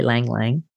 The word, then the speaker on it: ylang-ylang: You know, lavender works really good for sleep, but if you have anxiety, try Lang Lang, because Lang Lang oil, which is spelled Y-L-A-N-G, Y-L-A-N-G,